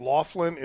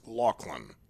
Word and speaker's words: Loughlin: It looks like Loughlin. It's pronounced Loughlin.